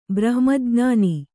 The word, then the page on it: ಬ್ರಹ್ಮಜ್ಞಾನಿ: brahma jñāni